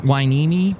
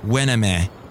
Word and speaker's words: Hueneme: it's Port Hueneme, but people would always say Port Hueneme.